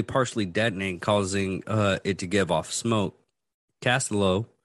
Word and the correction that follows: Castaldo: How do you pronounce that? Castaldo. – Richard Castaldo.